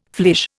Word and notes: flèche: Indian

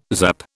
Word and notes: zap: British English

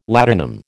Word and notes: ladanum: American English

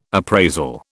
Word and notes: appraisal: British English

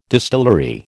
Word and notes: distillery: American English

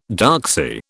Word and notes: doxy: British English

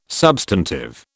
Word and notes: substantive: British English

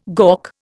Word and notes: gawk: Indian

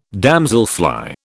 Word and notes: damselfly: British English

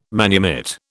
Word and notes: manumit: British English

